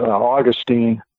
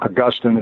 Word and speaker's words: Augustine: Augustine's a city in Florida, by the way. – Mm-hmm. – Augustine is the proper pronunciation.